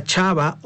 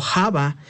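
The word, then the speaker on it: Chava (חוה): Solo como dato curioso, para que usted si lo quiere saber, interesante saber que la palabra Eva significa la que da vida, palabra en hebrea que se pronuncia Chava o Java.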